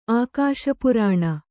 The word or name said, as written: ಆಕಾಶಪುರಾಣ